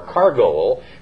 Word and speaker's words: chargol: The word beetle is 2728 in Strong's, and it comes from a word that's spelled C-H-A-R-G-O-L, or cargol, from a root meaning to leap suddenly, and means a leaping insect or a locust.